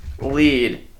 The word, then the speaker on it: Lied: And I recommend Elfin Lied, pronounced lead.